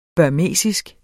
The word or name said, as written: burmesisk